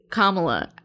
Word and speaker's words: Kamala: Is Kamala. Every time I drink, I say it wrong. – I'm here for you. – Just remember, Kamala, Mamala, Kamala, Mamala. We're just everybody.